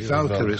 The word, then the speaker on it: Valkyries: Is it pronounced Valkyrie? – Valkyries, from the Valkyroch, if you'd like to do it that way.